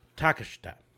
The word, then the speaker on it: Takeshita: The big one he was talking about is the person who had wrestled against Hangman Page on the night, which was, and I want to make sure I'm pronouncing the name, Konosuke Takeshita. Takeshita. There we go.